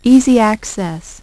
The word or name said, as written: Easy Access